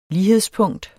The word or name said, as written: lighedspunkt